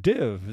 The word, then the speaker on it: DIIV: A band called Div. That's spelled D-I-I-V. Div, like live, I guess.